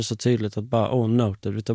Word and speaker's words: NOTD: Men jag älskar er merch för då står det noted och under inom parentes, ja, pronounced, pronounced noted.